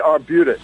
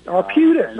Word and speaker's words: Arbutus: Is that the correct pronunciation, Arapus? – The Arbutus. – Arbutus. Okay.